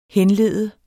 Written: henlede

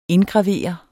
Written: indgravere

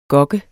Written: gok

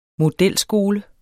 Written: modelskole

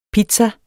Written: pizza

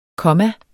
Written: komma

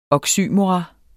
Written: oxymoron

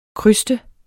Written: kryste

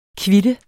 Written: kvit

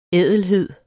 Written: ædelhed

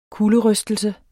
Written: kulderystelse

